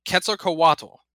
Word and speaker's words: Quetzalcoatl: Quetzalcoatl.